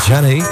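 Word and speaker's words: Zhané: En Jane, Z-H-A-N-E. Jane, zo spreek je het uit.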